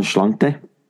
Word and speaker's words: Inno'sláinte: I'm going to say it wrong again. The Innoslint. – And the Schlante. – There you go.